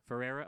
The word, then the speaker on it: Ferreira: Do you know how to say it? Ferreira, okay. Who